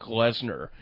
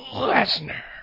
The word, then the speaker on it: Lesnar: Lesnar. – You said it wrong. – Brock Lesnar. – That's much better.